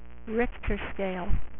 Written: rictor

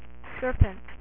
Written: Serpentor

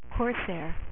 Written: corsair

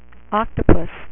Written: Doctor Octopus

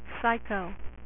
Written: Psyche Out

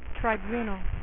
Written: living tribunal

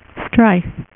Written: stryfe

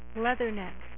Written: Leatherneck

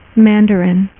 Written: Mandarin

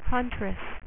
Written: Huntress

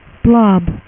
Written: Blob

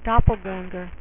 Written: Doppelganger

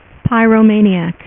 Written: Pyro

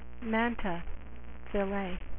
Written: ray fillet

